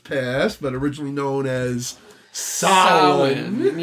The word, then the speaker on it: Samhain: Samhain.